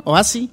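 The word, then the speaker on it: Houssay: ¿Cómo se pronuncia bien? Jussay. – Jussay, creo que. – Bernardo Jussay. – Jussay. – Se me mezcla el... Jussay, sí.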